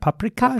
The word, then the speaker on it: paprikash: How do you say that? Paprikash? – Paprikash.